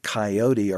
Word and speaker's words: coyote: and it was this three-syllable coyote, or coyote, as the Spanish said it.